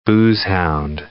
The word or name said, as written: boozehound